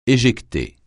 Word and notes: éjecter: francais

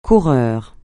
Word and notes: coureur: French

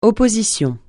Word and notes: opposition: francais

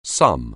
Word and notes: sum: anglais

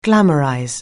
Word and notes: glamorize: anglais

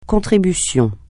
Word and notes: contribution: francais

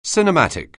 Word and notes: cinematic: anglais

